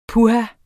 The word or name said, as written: puha